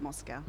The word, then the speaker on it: Mowczko: Yeah, Marg, but you do pronounce it Moscow.